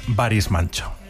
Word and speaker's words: Barış Manço: Claro, este era uno de los dos hippies a los que les fue muy bien y su nombre, un poco mejor pronunciado, es Barış Manço.